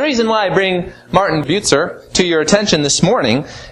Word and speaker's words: Bucer: The last name is pronounced a little differently than you would pronounce it in English, but Bucer is the correct pronunciation.